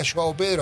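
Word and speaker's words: João Pedro: Joao Pedro, así se pronuncia.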